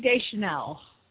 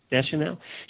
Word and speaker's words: Deschanel: Zoe Deschanel. – Deschanel.